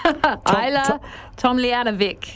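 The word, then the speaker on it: Ajla Tomljanović: Ayla Tomljanovic.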